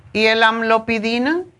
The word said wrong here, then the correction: Amlodipina: Amlodipina es de... Amlodipina. Siempre lo digo mal.